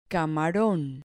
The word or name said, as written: camaron